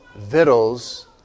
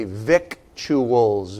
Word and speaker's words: victuals: The biblical word victuals looks like it would be pronounced victuals. And often when we're young, as we sound out the word and reading the scriptures, we say victuals, but the word's actually pronounced victuals.